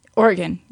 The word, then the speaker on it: Oregon: And so the last Blockbuster is actually located in Bend, Oregon. – Oregon. – I feel like I always say it wrong. – Oregon.